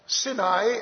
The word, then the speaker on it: Sinai: Sinai, actually, is the pronunciation, and that is also where some of the Sinites had settled.